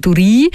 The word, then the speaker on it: Duri: Also nicht Duri, sondern Duri. Das klingt auch gerade so schön nach Engadin, oder? Also nicht für ungut, dass ich den Namen nicht ganz so schön ausgesprochen habe.